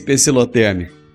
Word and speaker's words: pecilotérmico: E eu não conseguia lembrar de jeito nenhum, e agora eu me lembrei dessa palavra, que é o tal do pecilotérmico.